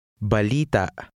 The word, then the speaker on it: balita: Remember to place the stress on the second to last vowel. – Balita.